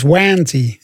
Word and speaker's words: Wanty: Je moet het ook anders uitspreken. Het is Wanty, is het ook. – Is het Wanty? – Wanty.